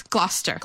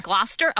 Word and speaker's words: Gloucester: Okay, how do you say it? – Because Gloucester? We all say things. Gloucester. – Gloucester.